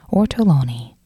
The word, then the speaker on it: Ortolani: Oh, damn, I keep pronouncing the last name wrong, I apologize. Ortolani.